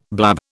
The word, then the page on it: blab: British English